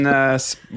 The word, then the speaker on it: Stedman: That's not my middle name, but and Stedman. – Chris Dog Stedman. – Stedman, which is spelled like Oprah's boyfriend, Stedman.